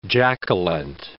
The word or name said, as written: Jack-a-Lent